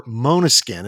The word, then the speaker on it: Måneskin: That's the song Blah Blah Blah by Måneskin, or Måneskin is how it's pronounced, and that's from their new album called Rush.